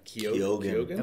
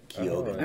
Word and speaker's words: Keoghan: Keoghan. – Okay. – Keoghan.